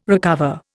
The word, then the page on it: recover: Indian